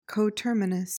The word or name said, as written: coterminous